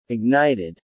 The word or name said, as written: ignited